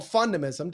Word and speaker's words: fundamism: So if my philosophy, which you mentioned earlier and even pronounced it correctly, kudos to you, is called fundamism, defined as the fundamentals of a fun and optimistic lifestyle, it's really built off this premise of brain pattern interrupts.